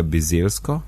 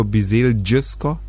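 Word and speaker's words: Bizeljsko: tam je bilo bizelsko in je rekla bizelsko, brez, ni bilo bizel džesko.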